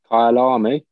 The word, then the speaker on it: Kyalami: Is that how I pronounce it? Kalamayi? Kalamayi? How do you say it? – Kyalami.